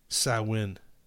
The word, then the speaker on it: Samhain: Or how they pronounced it is Samhain.